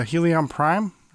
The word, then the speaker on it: Helion Prime: It's Helion Prime. Am I saying that right? – Yes. No, you're totally saying it right.